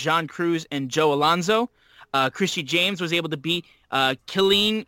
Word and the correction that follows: Kylan King: Oh, Kylan King is... – Kylan King. My yes, bad.